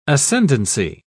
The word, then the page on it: ascendency: British English